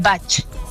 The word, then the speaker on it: batch: B-A-C-H. – Ah, un bat, ¿ok? Porque, perdona, yo, te corrijo un poco. – No. Por favor. – No, es que aquí los kiwis tienen cada uno su pronunciación. Un batch.